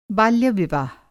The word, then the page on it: बाल्यविवाह: Hindi